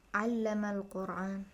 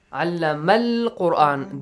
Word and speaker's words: al-Qur'an: Like al-Qur'an. Al-Qur'an. The lam is not silent, al-Qur'an, but alif or hamzatul wasl is skipped, it's silent.